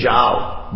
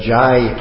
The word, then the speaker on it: jao: Unfortunately, we pronounce it wrong. We say, Koto nidra jai Maya. All glories to Maya that I remain sleeping. And it's funny on one hand, but the thing is, when you say those words, it's an imprint on the brain. If you pronounce it wrong, if you say the wrong thing, that's what goes in. You have to, jao means go, not jai, which means all glories.